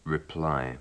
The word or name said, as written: reply